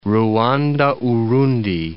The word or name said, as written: Ruanda-Urundi